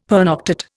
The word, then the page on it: pernoctate: Indian